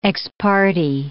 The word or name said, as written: ex parte